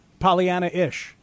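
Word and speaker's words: Pollyannaish: Pollyanna-ish. You got to add that A, man. Add that A. It's an extra syllable you're leaving out, and I think that's wrong.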